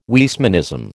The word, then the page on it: Weismannism: American English